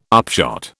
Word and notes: upshot: British English

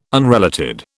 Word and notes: unrelated: British English

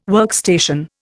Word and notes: workstation: Indian

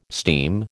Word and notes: steam: American English

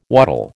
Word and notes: waddle: American English

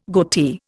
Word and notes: goatee: Indian

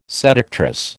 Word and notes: seductress: American English

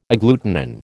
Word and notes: agglutinin: American English

